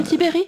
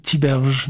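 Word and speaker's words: Tiberj: Il veut pas qu'on l'appelle Tibéri? – Tiberge, non. – Lui, il s'appelle Tibéri. – D'accord, ok. – Ça alors, s'écrit Tiberge.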